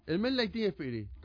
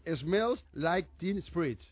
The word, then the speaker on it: Smells Like Teen Spirit: Eso no está bien pronunciado, hermano. – Smells Like Teen Spirit.